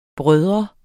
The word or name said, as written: bror